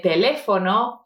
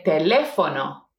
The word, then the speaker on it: teléfono: And then as a response, we can have, mi número de teléfono es. See, let's repeat this part. Mi número de teléfono.